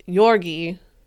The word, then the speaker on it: Georgi: So, yeah, his name is spelled Georgie. – Yeah, Yorgi. but it's pronounced Yorgi, which is Yuri, which is also it's called Yuri.